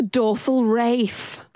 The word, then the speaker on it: raphe: It's called the dorsal rafe. Like Ray Fiennes. – Is that how it's spelled? – It's spelled R-A-P-H-E. So not like Ray Fiennes, because that'd be sort of dorsal Ralph.